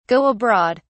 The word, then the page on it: go abroad: British English